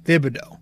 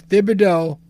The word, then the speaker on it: Thibodeau: So when you pronounce his entire name, it is T-H. It's Thibodeau. But when you give him the nickname, it's Tibbs. So I finally figured it out because they were saying it on the broadcast tonight. And I figured I was able to figure out the distinction. So you do... You pronounce the TH when you say his entire name. But when you fucking, you know, say his nickname, you just pronounce the T. So it's Thibodeau and Tibbs.